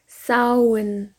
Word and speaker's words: Samhain: Und man kann sozusagen sagen, dass das M, das H und das A in der Mitte des Wortes stumm ist und als ein U ausgesprochen wird. Also Samhain.